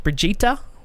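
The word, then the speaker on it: Brigitte: After a series of teasers, Blizzard has confirmed Overwatch Hero 27 is Brigitta, it says Bridget, but it's pronounced Brigitta Lindholm, who will be available on the PTR at the moment.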